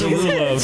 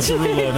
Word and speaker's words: Derulo: Right in solo, Jason Derulo. Did I say his name? Yes. – Derulo. Derulo. – Derulo.